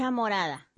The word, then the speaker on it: morada: Is it chicha morado? Or how do you say it? – Chicha morada.